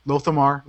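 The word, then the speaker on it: Lor'themar: But yeah, there's stuff with Lothar, which I can never say his name. – Lothamar? – Lothamar.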